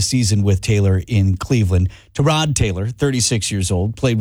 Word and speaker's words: Tyrod: It's correctly pronounced Tyrod, but he doesn't correct people.